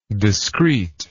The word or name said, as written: discreteness